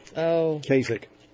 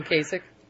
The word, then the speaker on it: Kasich: What's his name? Lasik? What's the governor of Ohio? I can never pronounce his name. – Kasich. John Kasich. – Kasich.